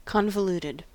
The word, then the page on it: convoluted: American English